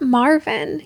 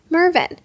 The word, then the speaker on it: Mervyn: No, not Marvin, he would inevitably correct you. Mervyn rhymes with swervin.